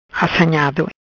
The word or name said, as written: Assanhado